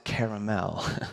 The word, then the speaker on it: Carmel: Elijah climbed on top of Mount Carmel. Some pronounce it Caramel.